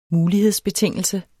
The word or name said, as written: mulighedsbetingelse